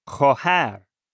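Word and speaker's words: Khohar (خواهر): Khohar.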